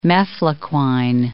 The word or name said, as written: mefloquine